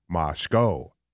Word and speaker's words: Moscow: They also get very angry in, not super angry, but in Moscow, Idaho, which is spelled exactly like Moscow, but pronounced Moscow, home of the University of Idaho.